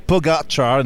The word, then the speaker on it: Pogačar: Tadej Pogačar, not Pogačar.